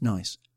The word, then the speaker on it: gneiss: It's spelt G-N-E-I-S-S and pronounced nice, which is nice, and is amongst the oldest rock in the world.